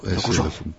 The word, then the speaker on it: Dokuso: Es el acento en la O. – Dokuso.